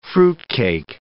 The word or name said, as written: fruitcake